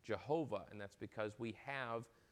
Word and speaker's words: Jehovah: and we get Yehovah, or Jehovah, as we call it, because we pronounce the J in English.